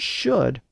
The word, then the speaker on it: should: That also helps to explain why words like would and should are spelled with an L, even though we don't pronounce those L's today.